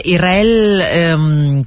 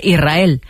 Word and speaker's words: Israel: Y la S con la R es difícil de pronunciar. La gente dice Israel, sin verdad que suene la S.